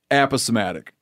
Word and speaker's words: aposematic: I imagine for that system to work, aposematic, right? Am I saying it right? – Yeah, that's right.